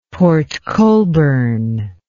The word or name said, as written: port colborne